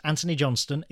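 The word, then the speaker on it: Antony Johnston: And so that is Anthony Johnston.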